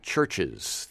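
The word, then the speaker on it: CHVRCHES: On June 15th, the Scottish group called Churches, the band that's spelled with a V, C-H-V-R-C-H-E-S, is playing at McCarran Park in Williamsburg as part of Northside Fest.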